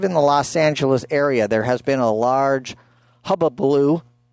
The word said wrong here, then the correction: hullabaloo: I'm not even, Miss Victoria, how do you say that word? Hubbubaloo? – Hullabaloo.